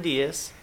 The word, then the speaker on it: Diaz: My name's Colonel John Diaz, and I spell that J-O-H-N, last name spelled D-I-A-Z.